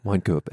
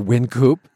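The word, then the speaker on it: Wynkoop: Okay, these are obviously both street names in downtown Denver, Winekoop and Larimer, or Winkoop, depending on your pronunciation.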